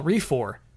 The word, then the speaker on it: Refor: I'm suggesting a game that I call Refor, pronounced like Recore, just with an F.